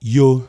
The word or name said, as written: Yo